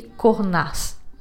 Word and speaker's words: Cornas: Temos então Côte Rôti, Saint-Joseph, Hermitage, Cruz Hermitage e Cornas. Pronuncia assim, com um S no final mesmo e curiosidade.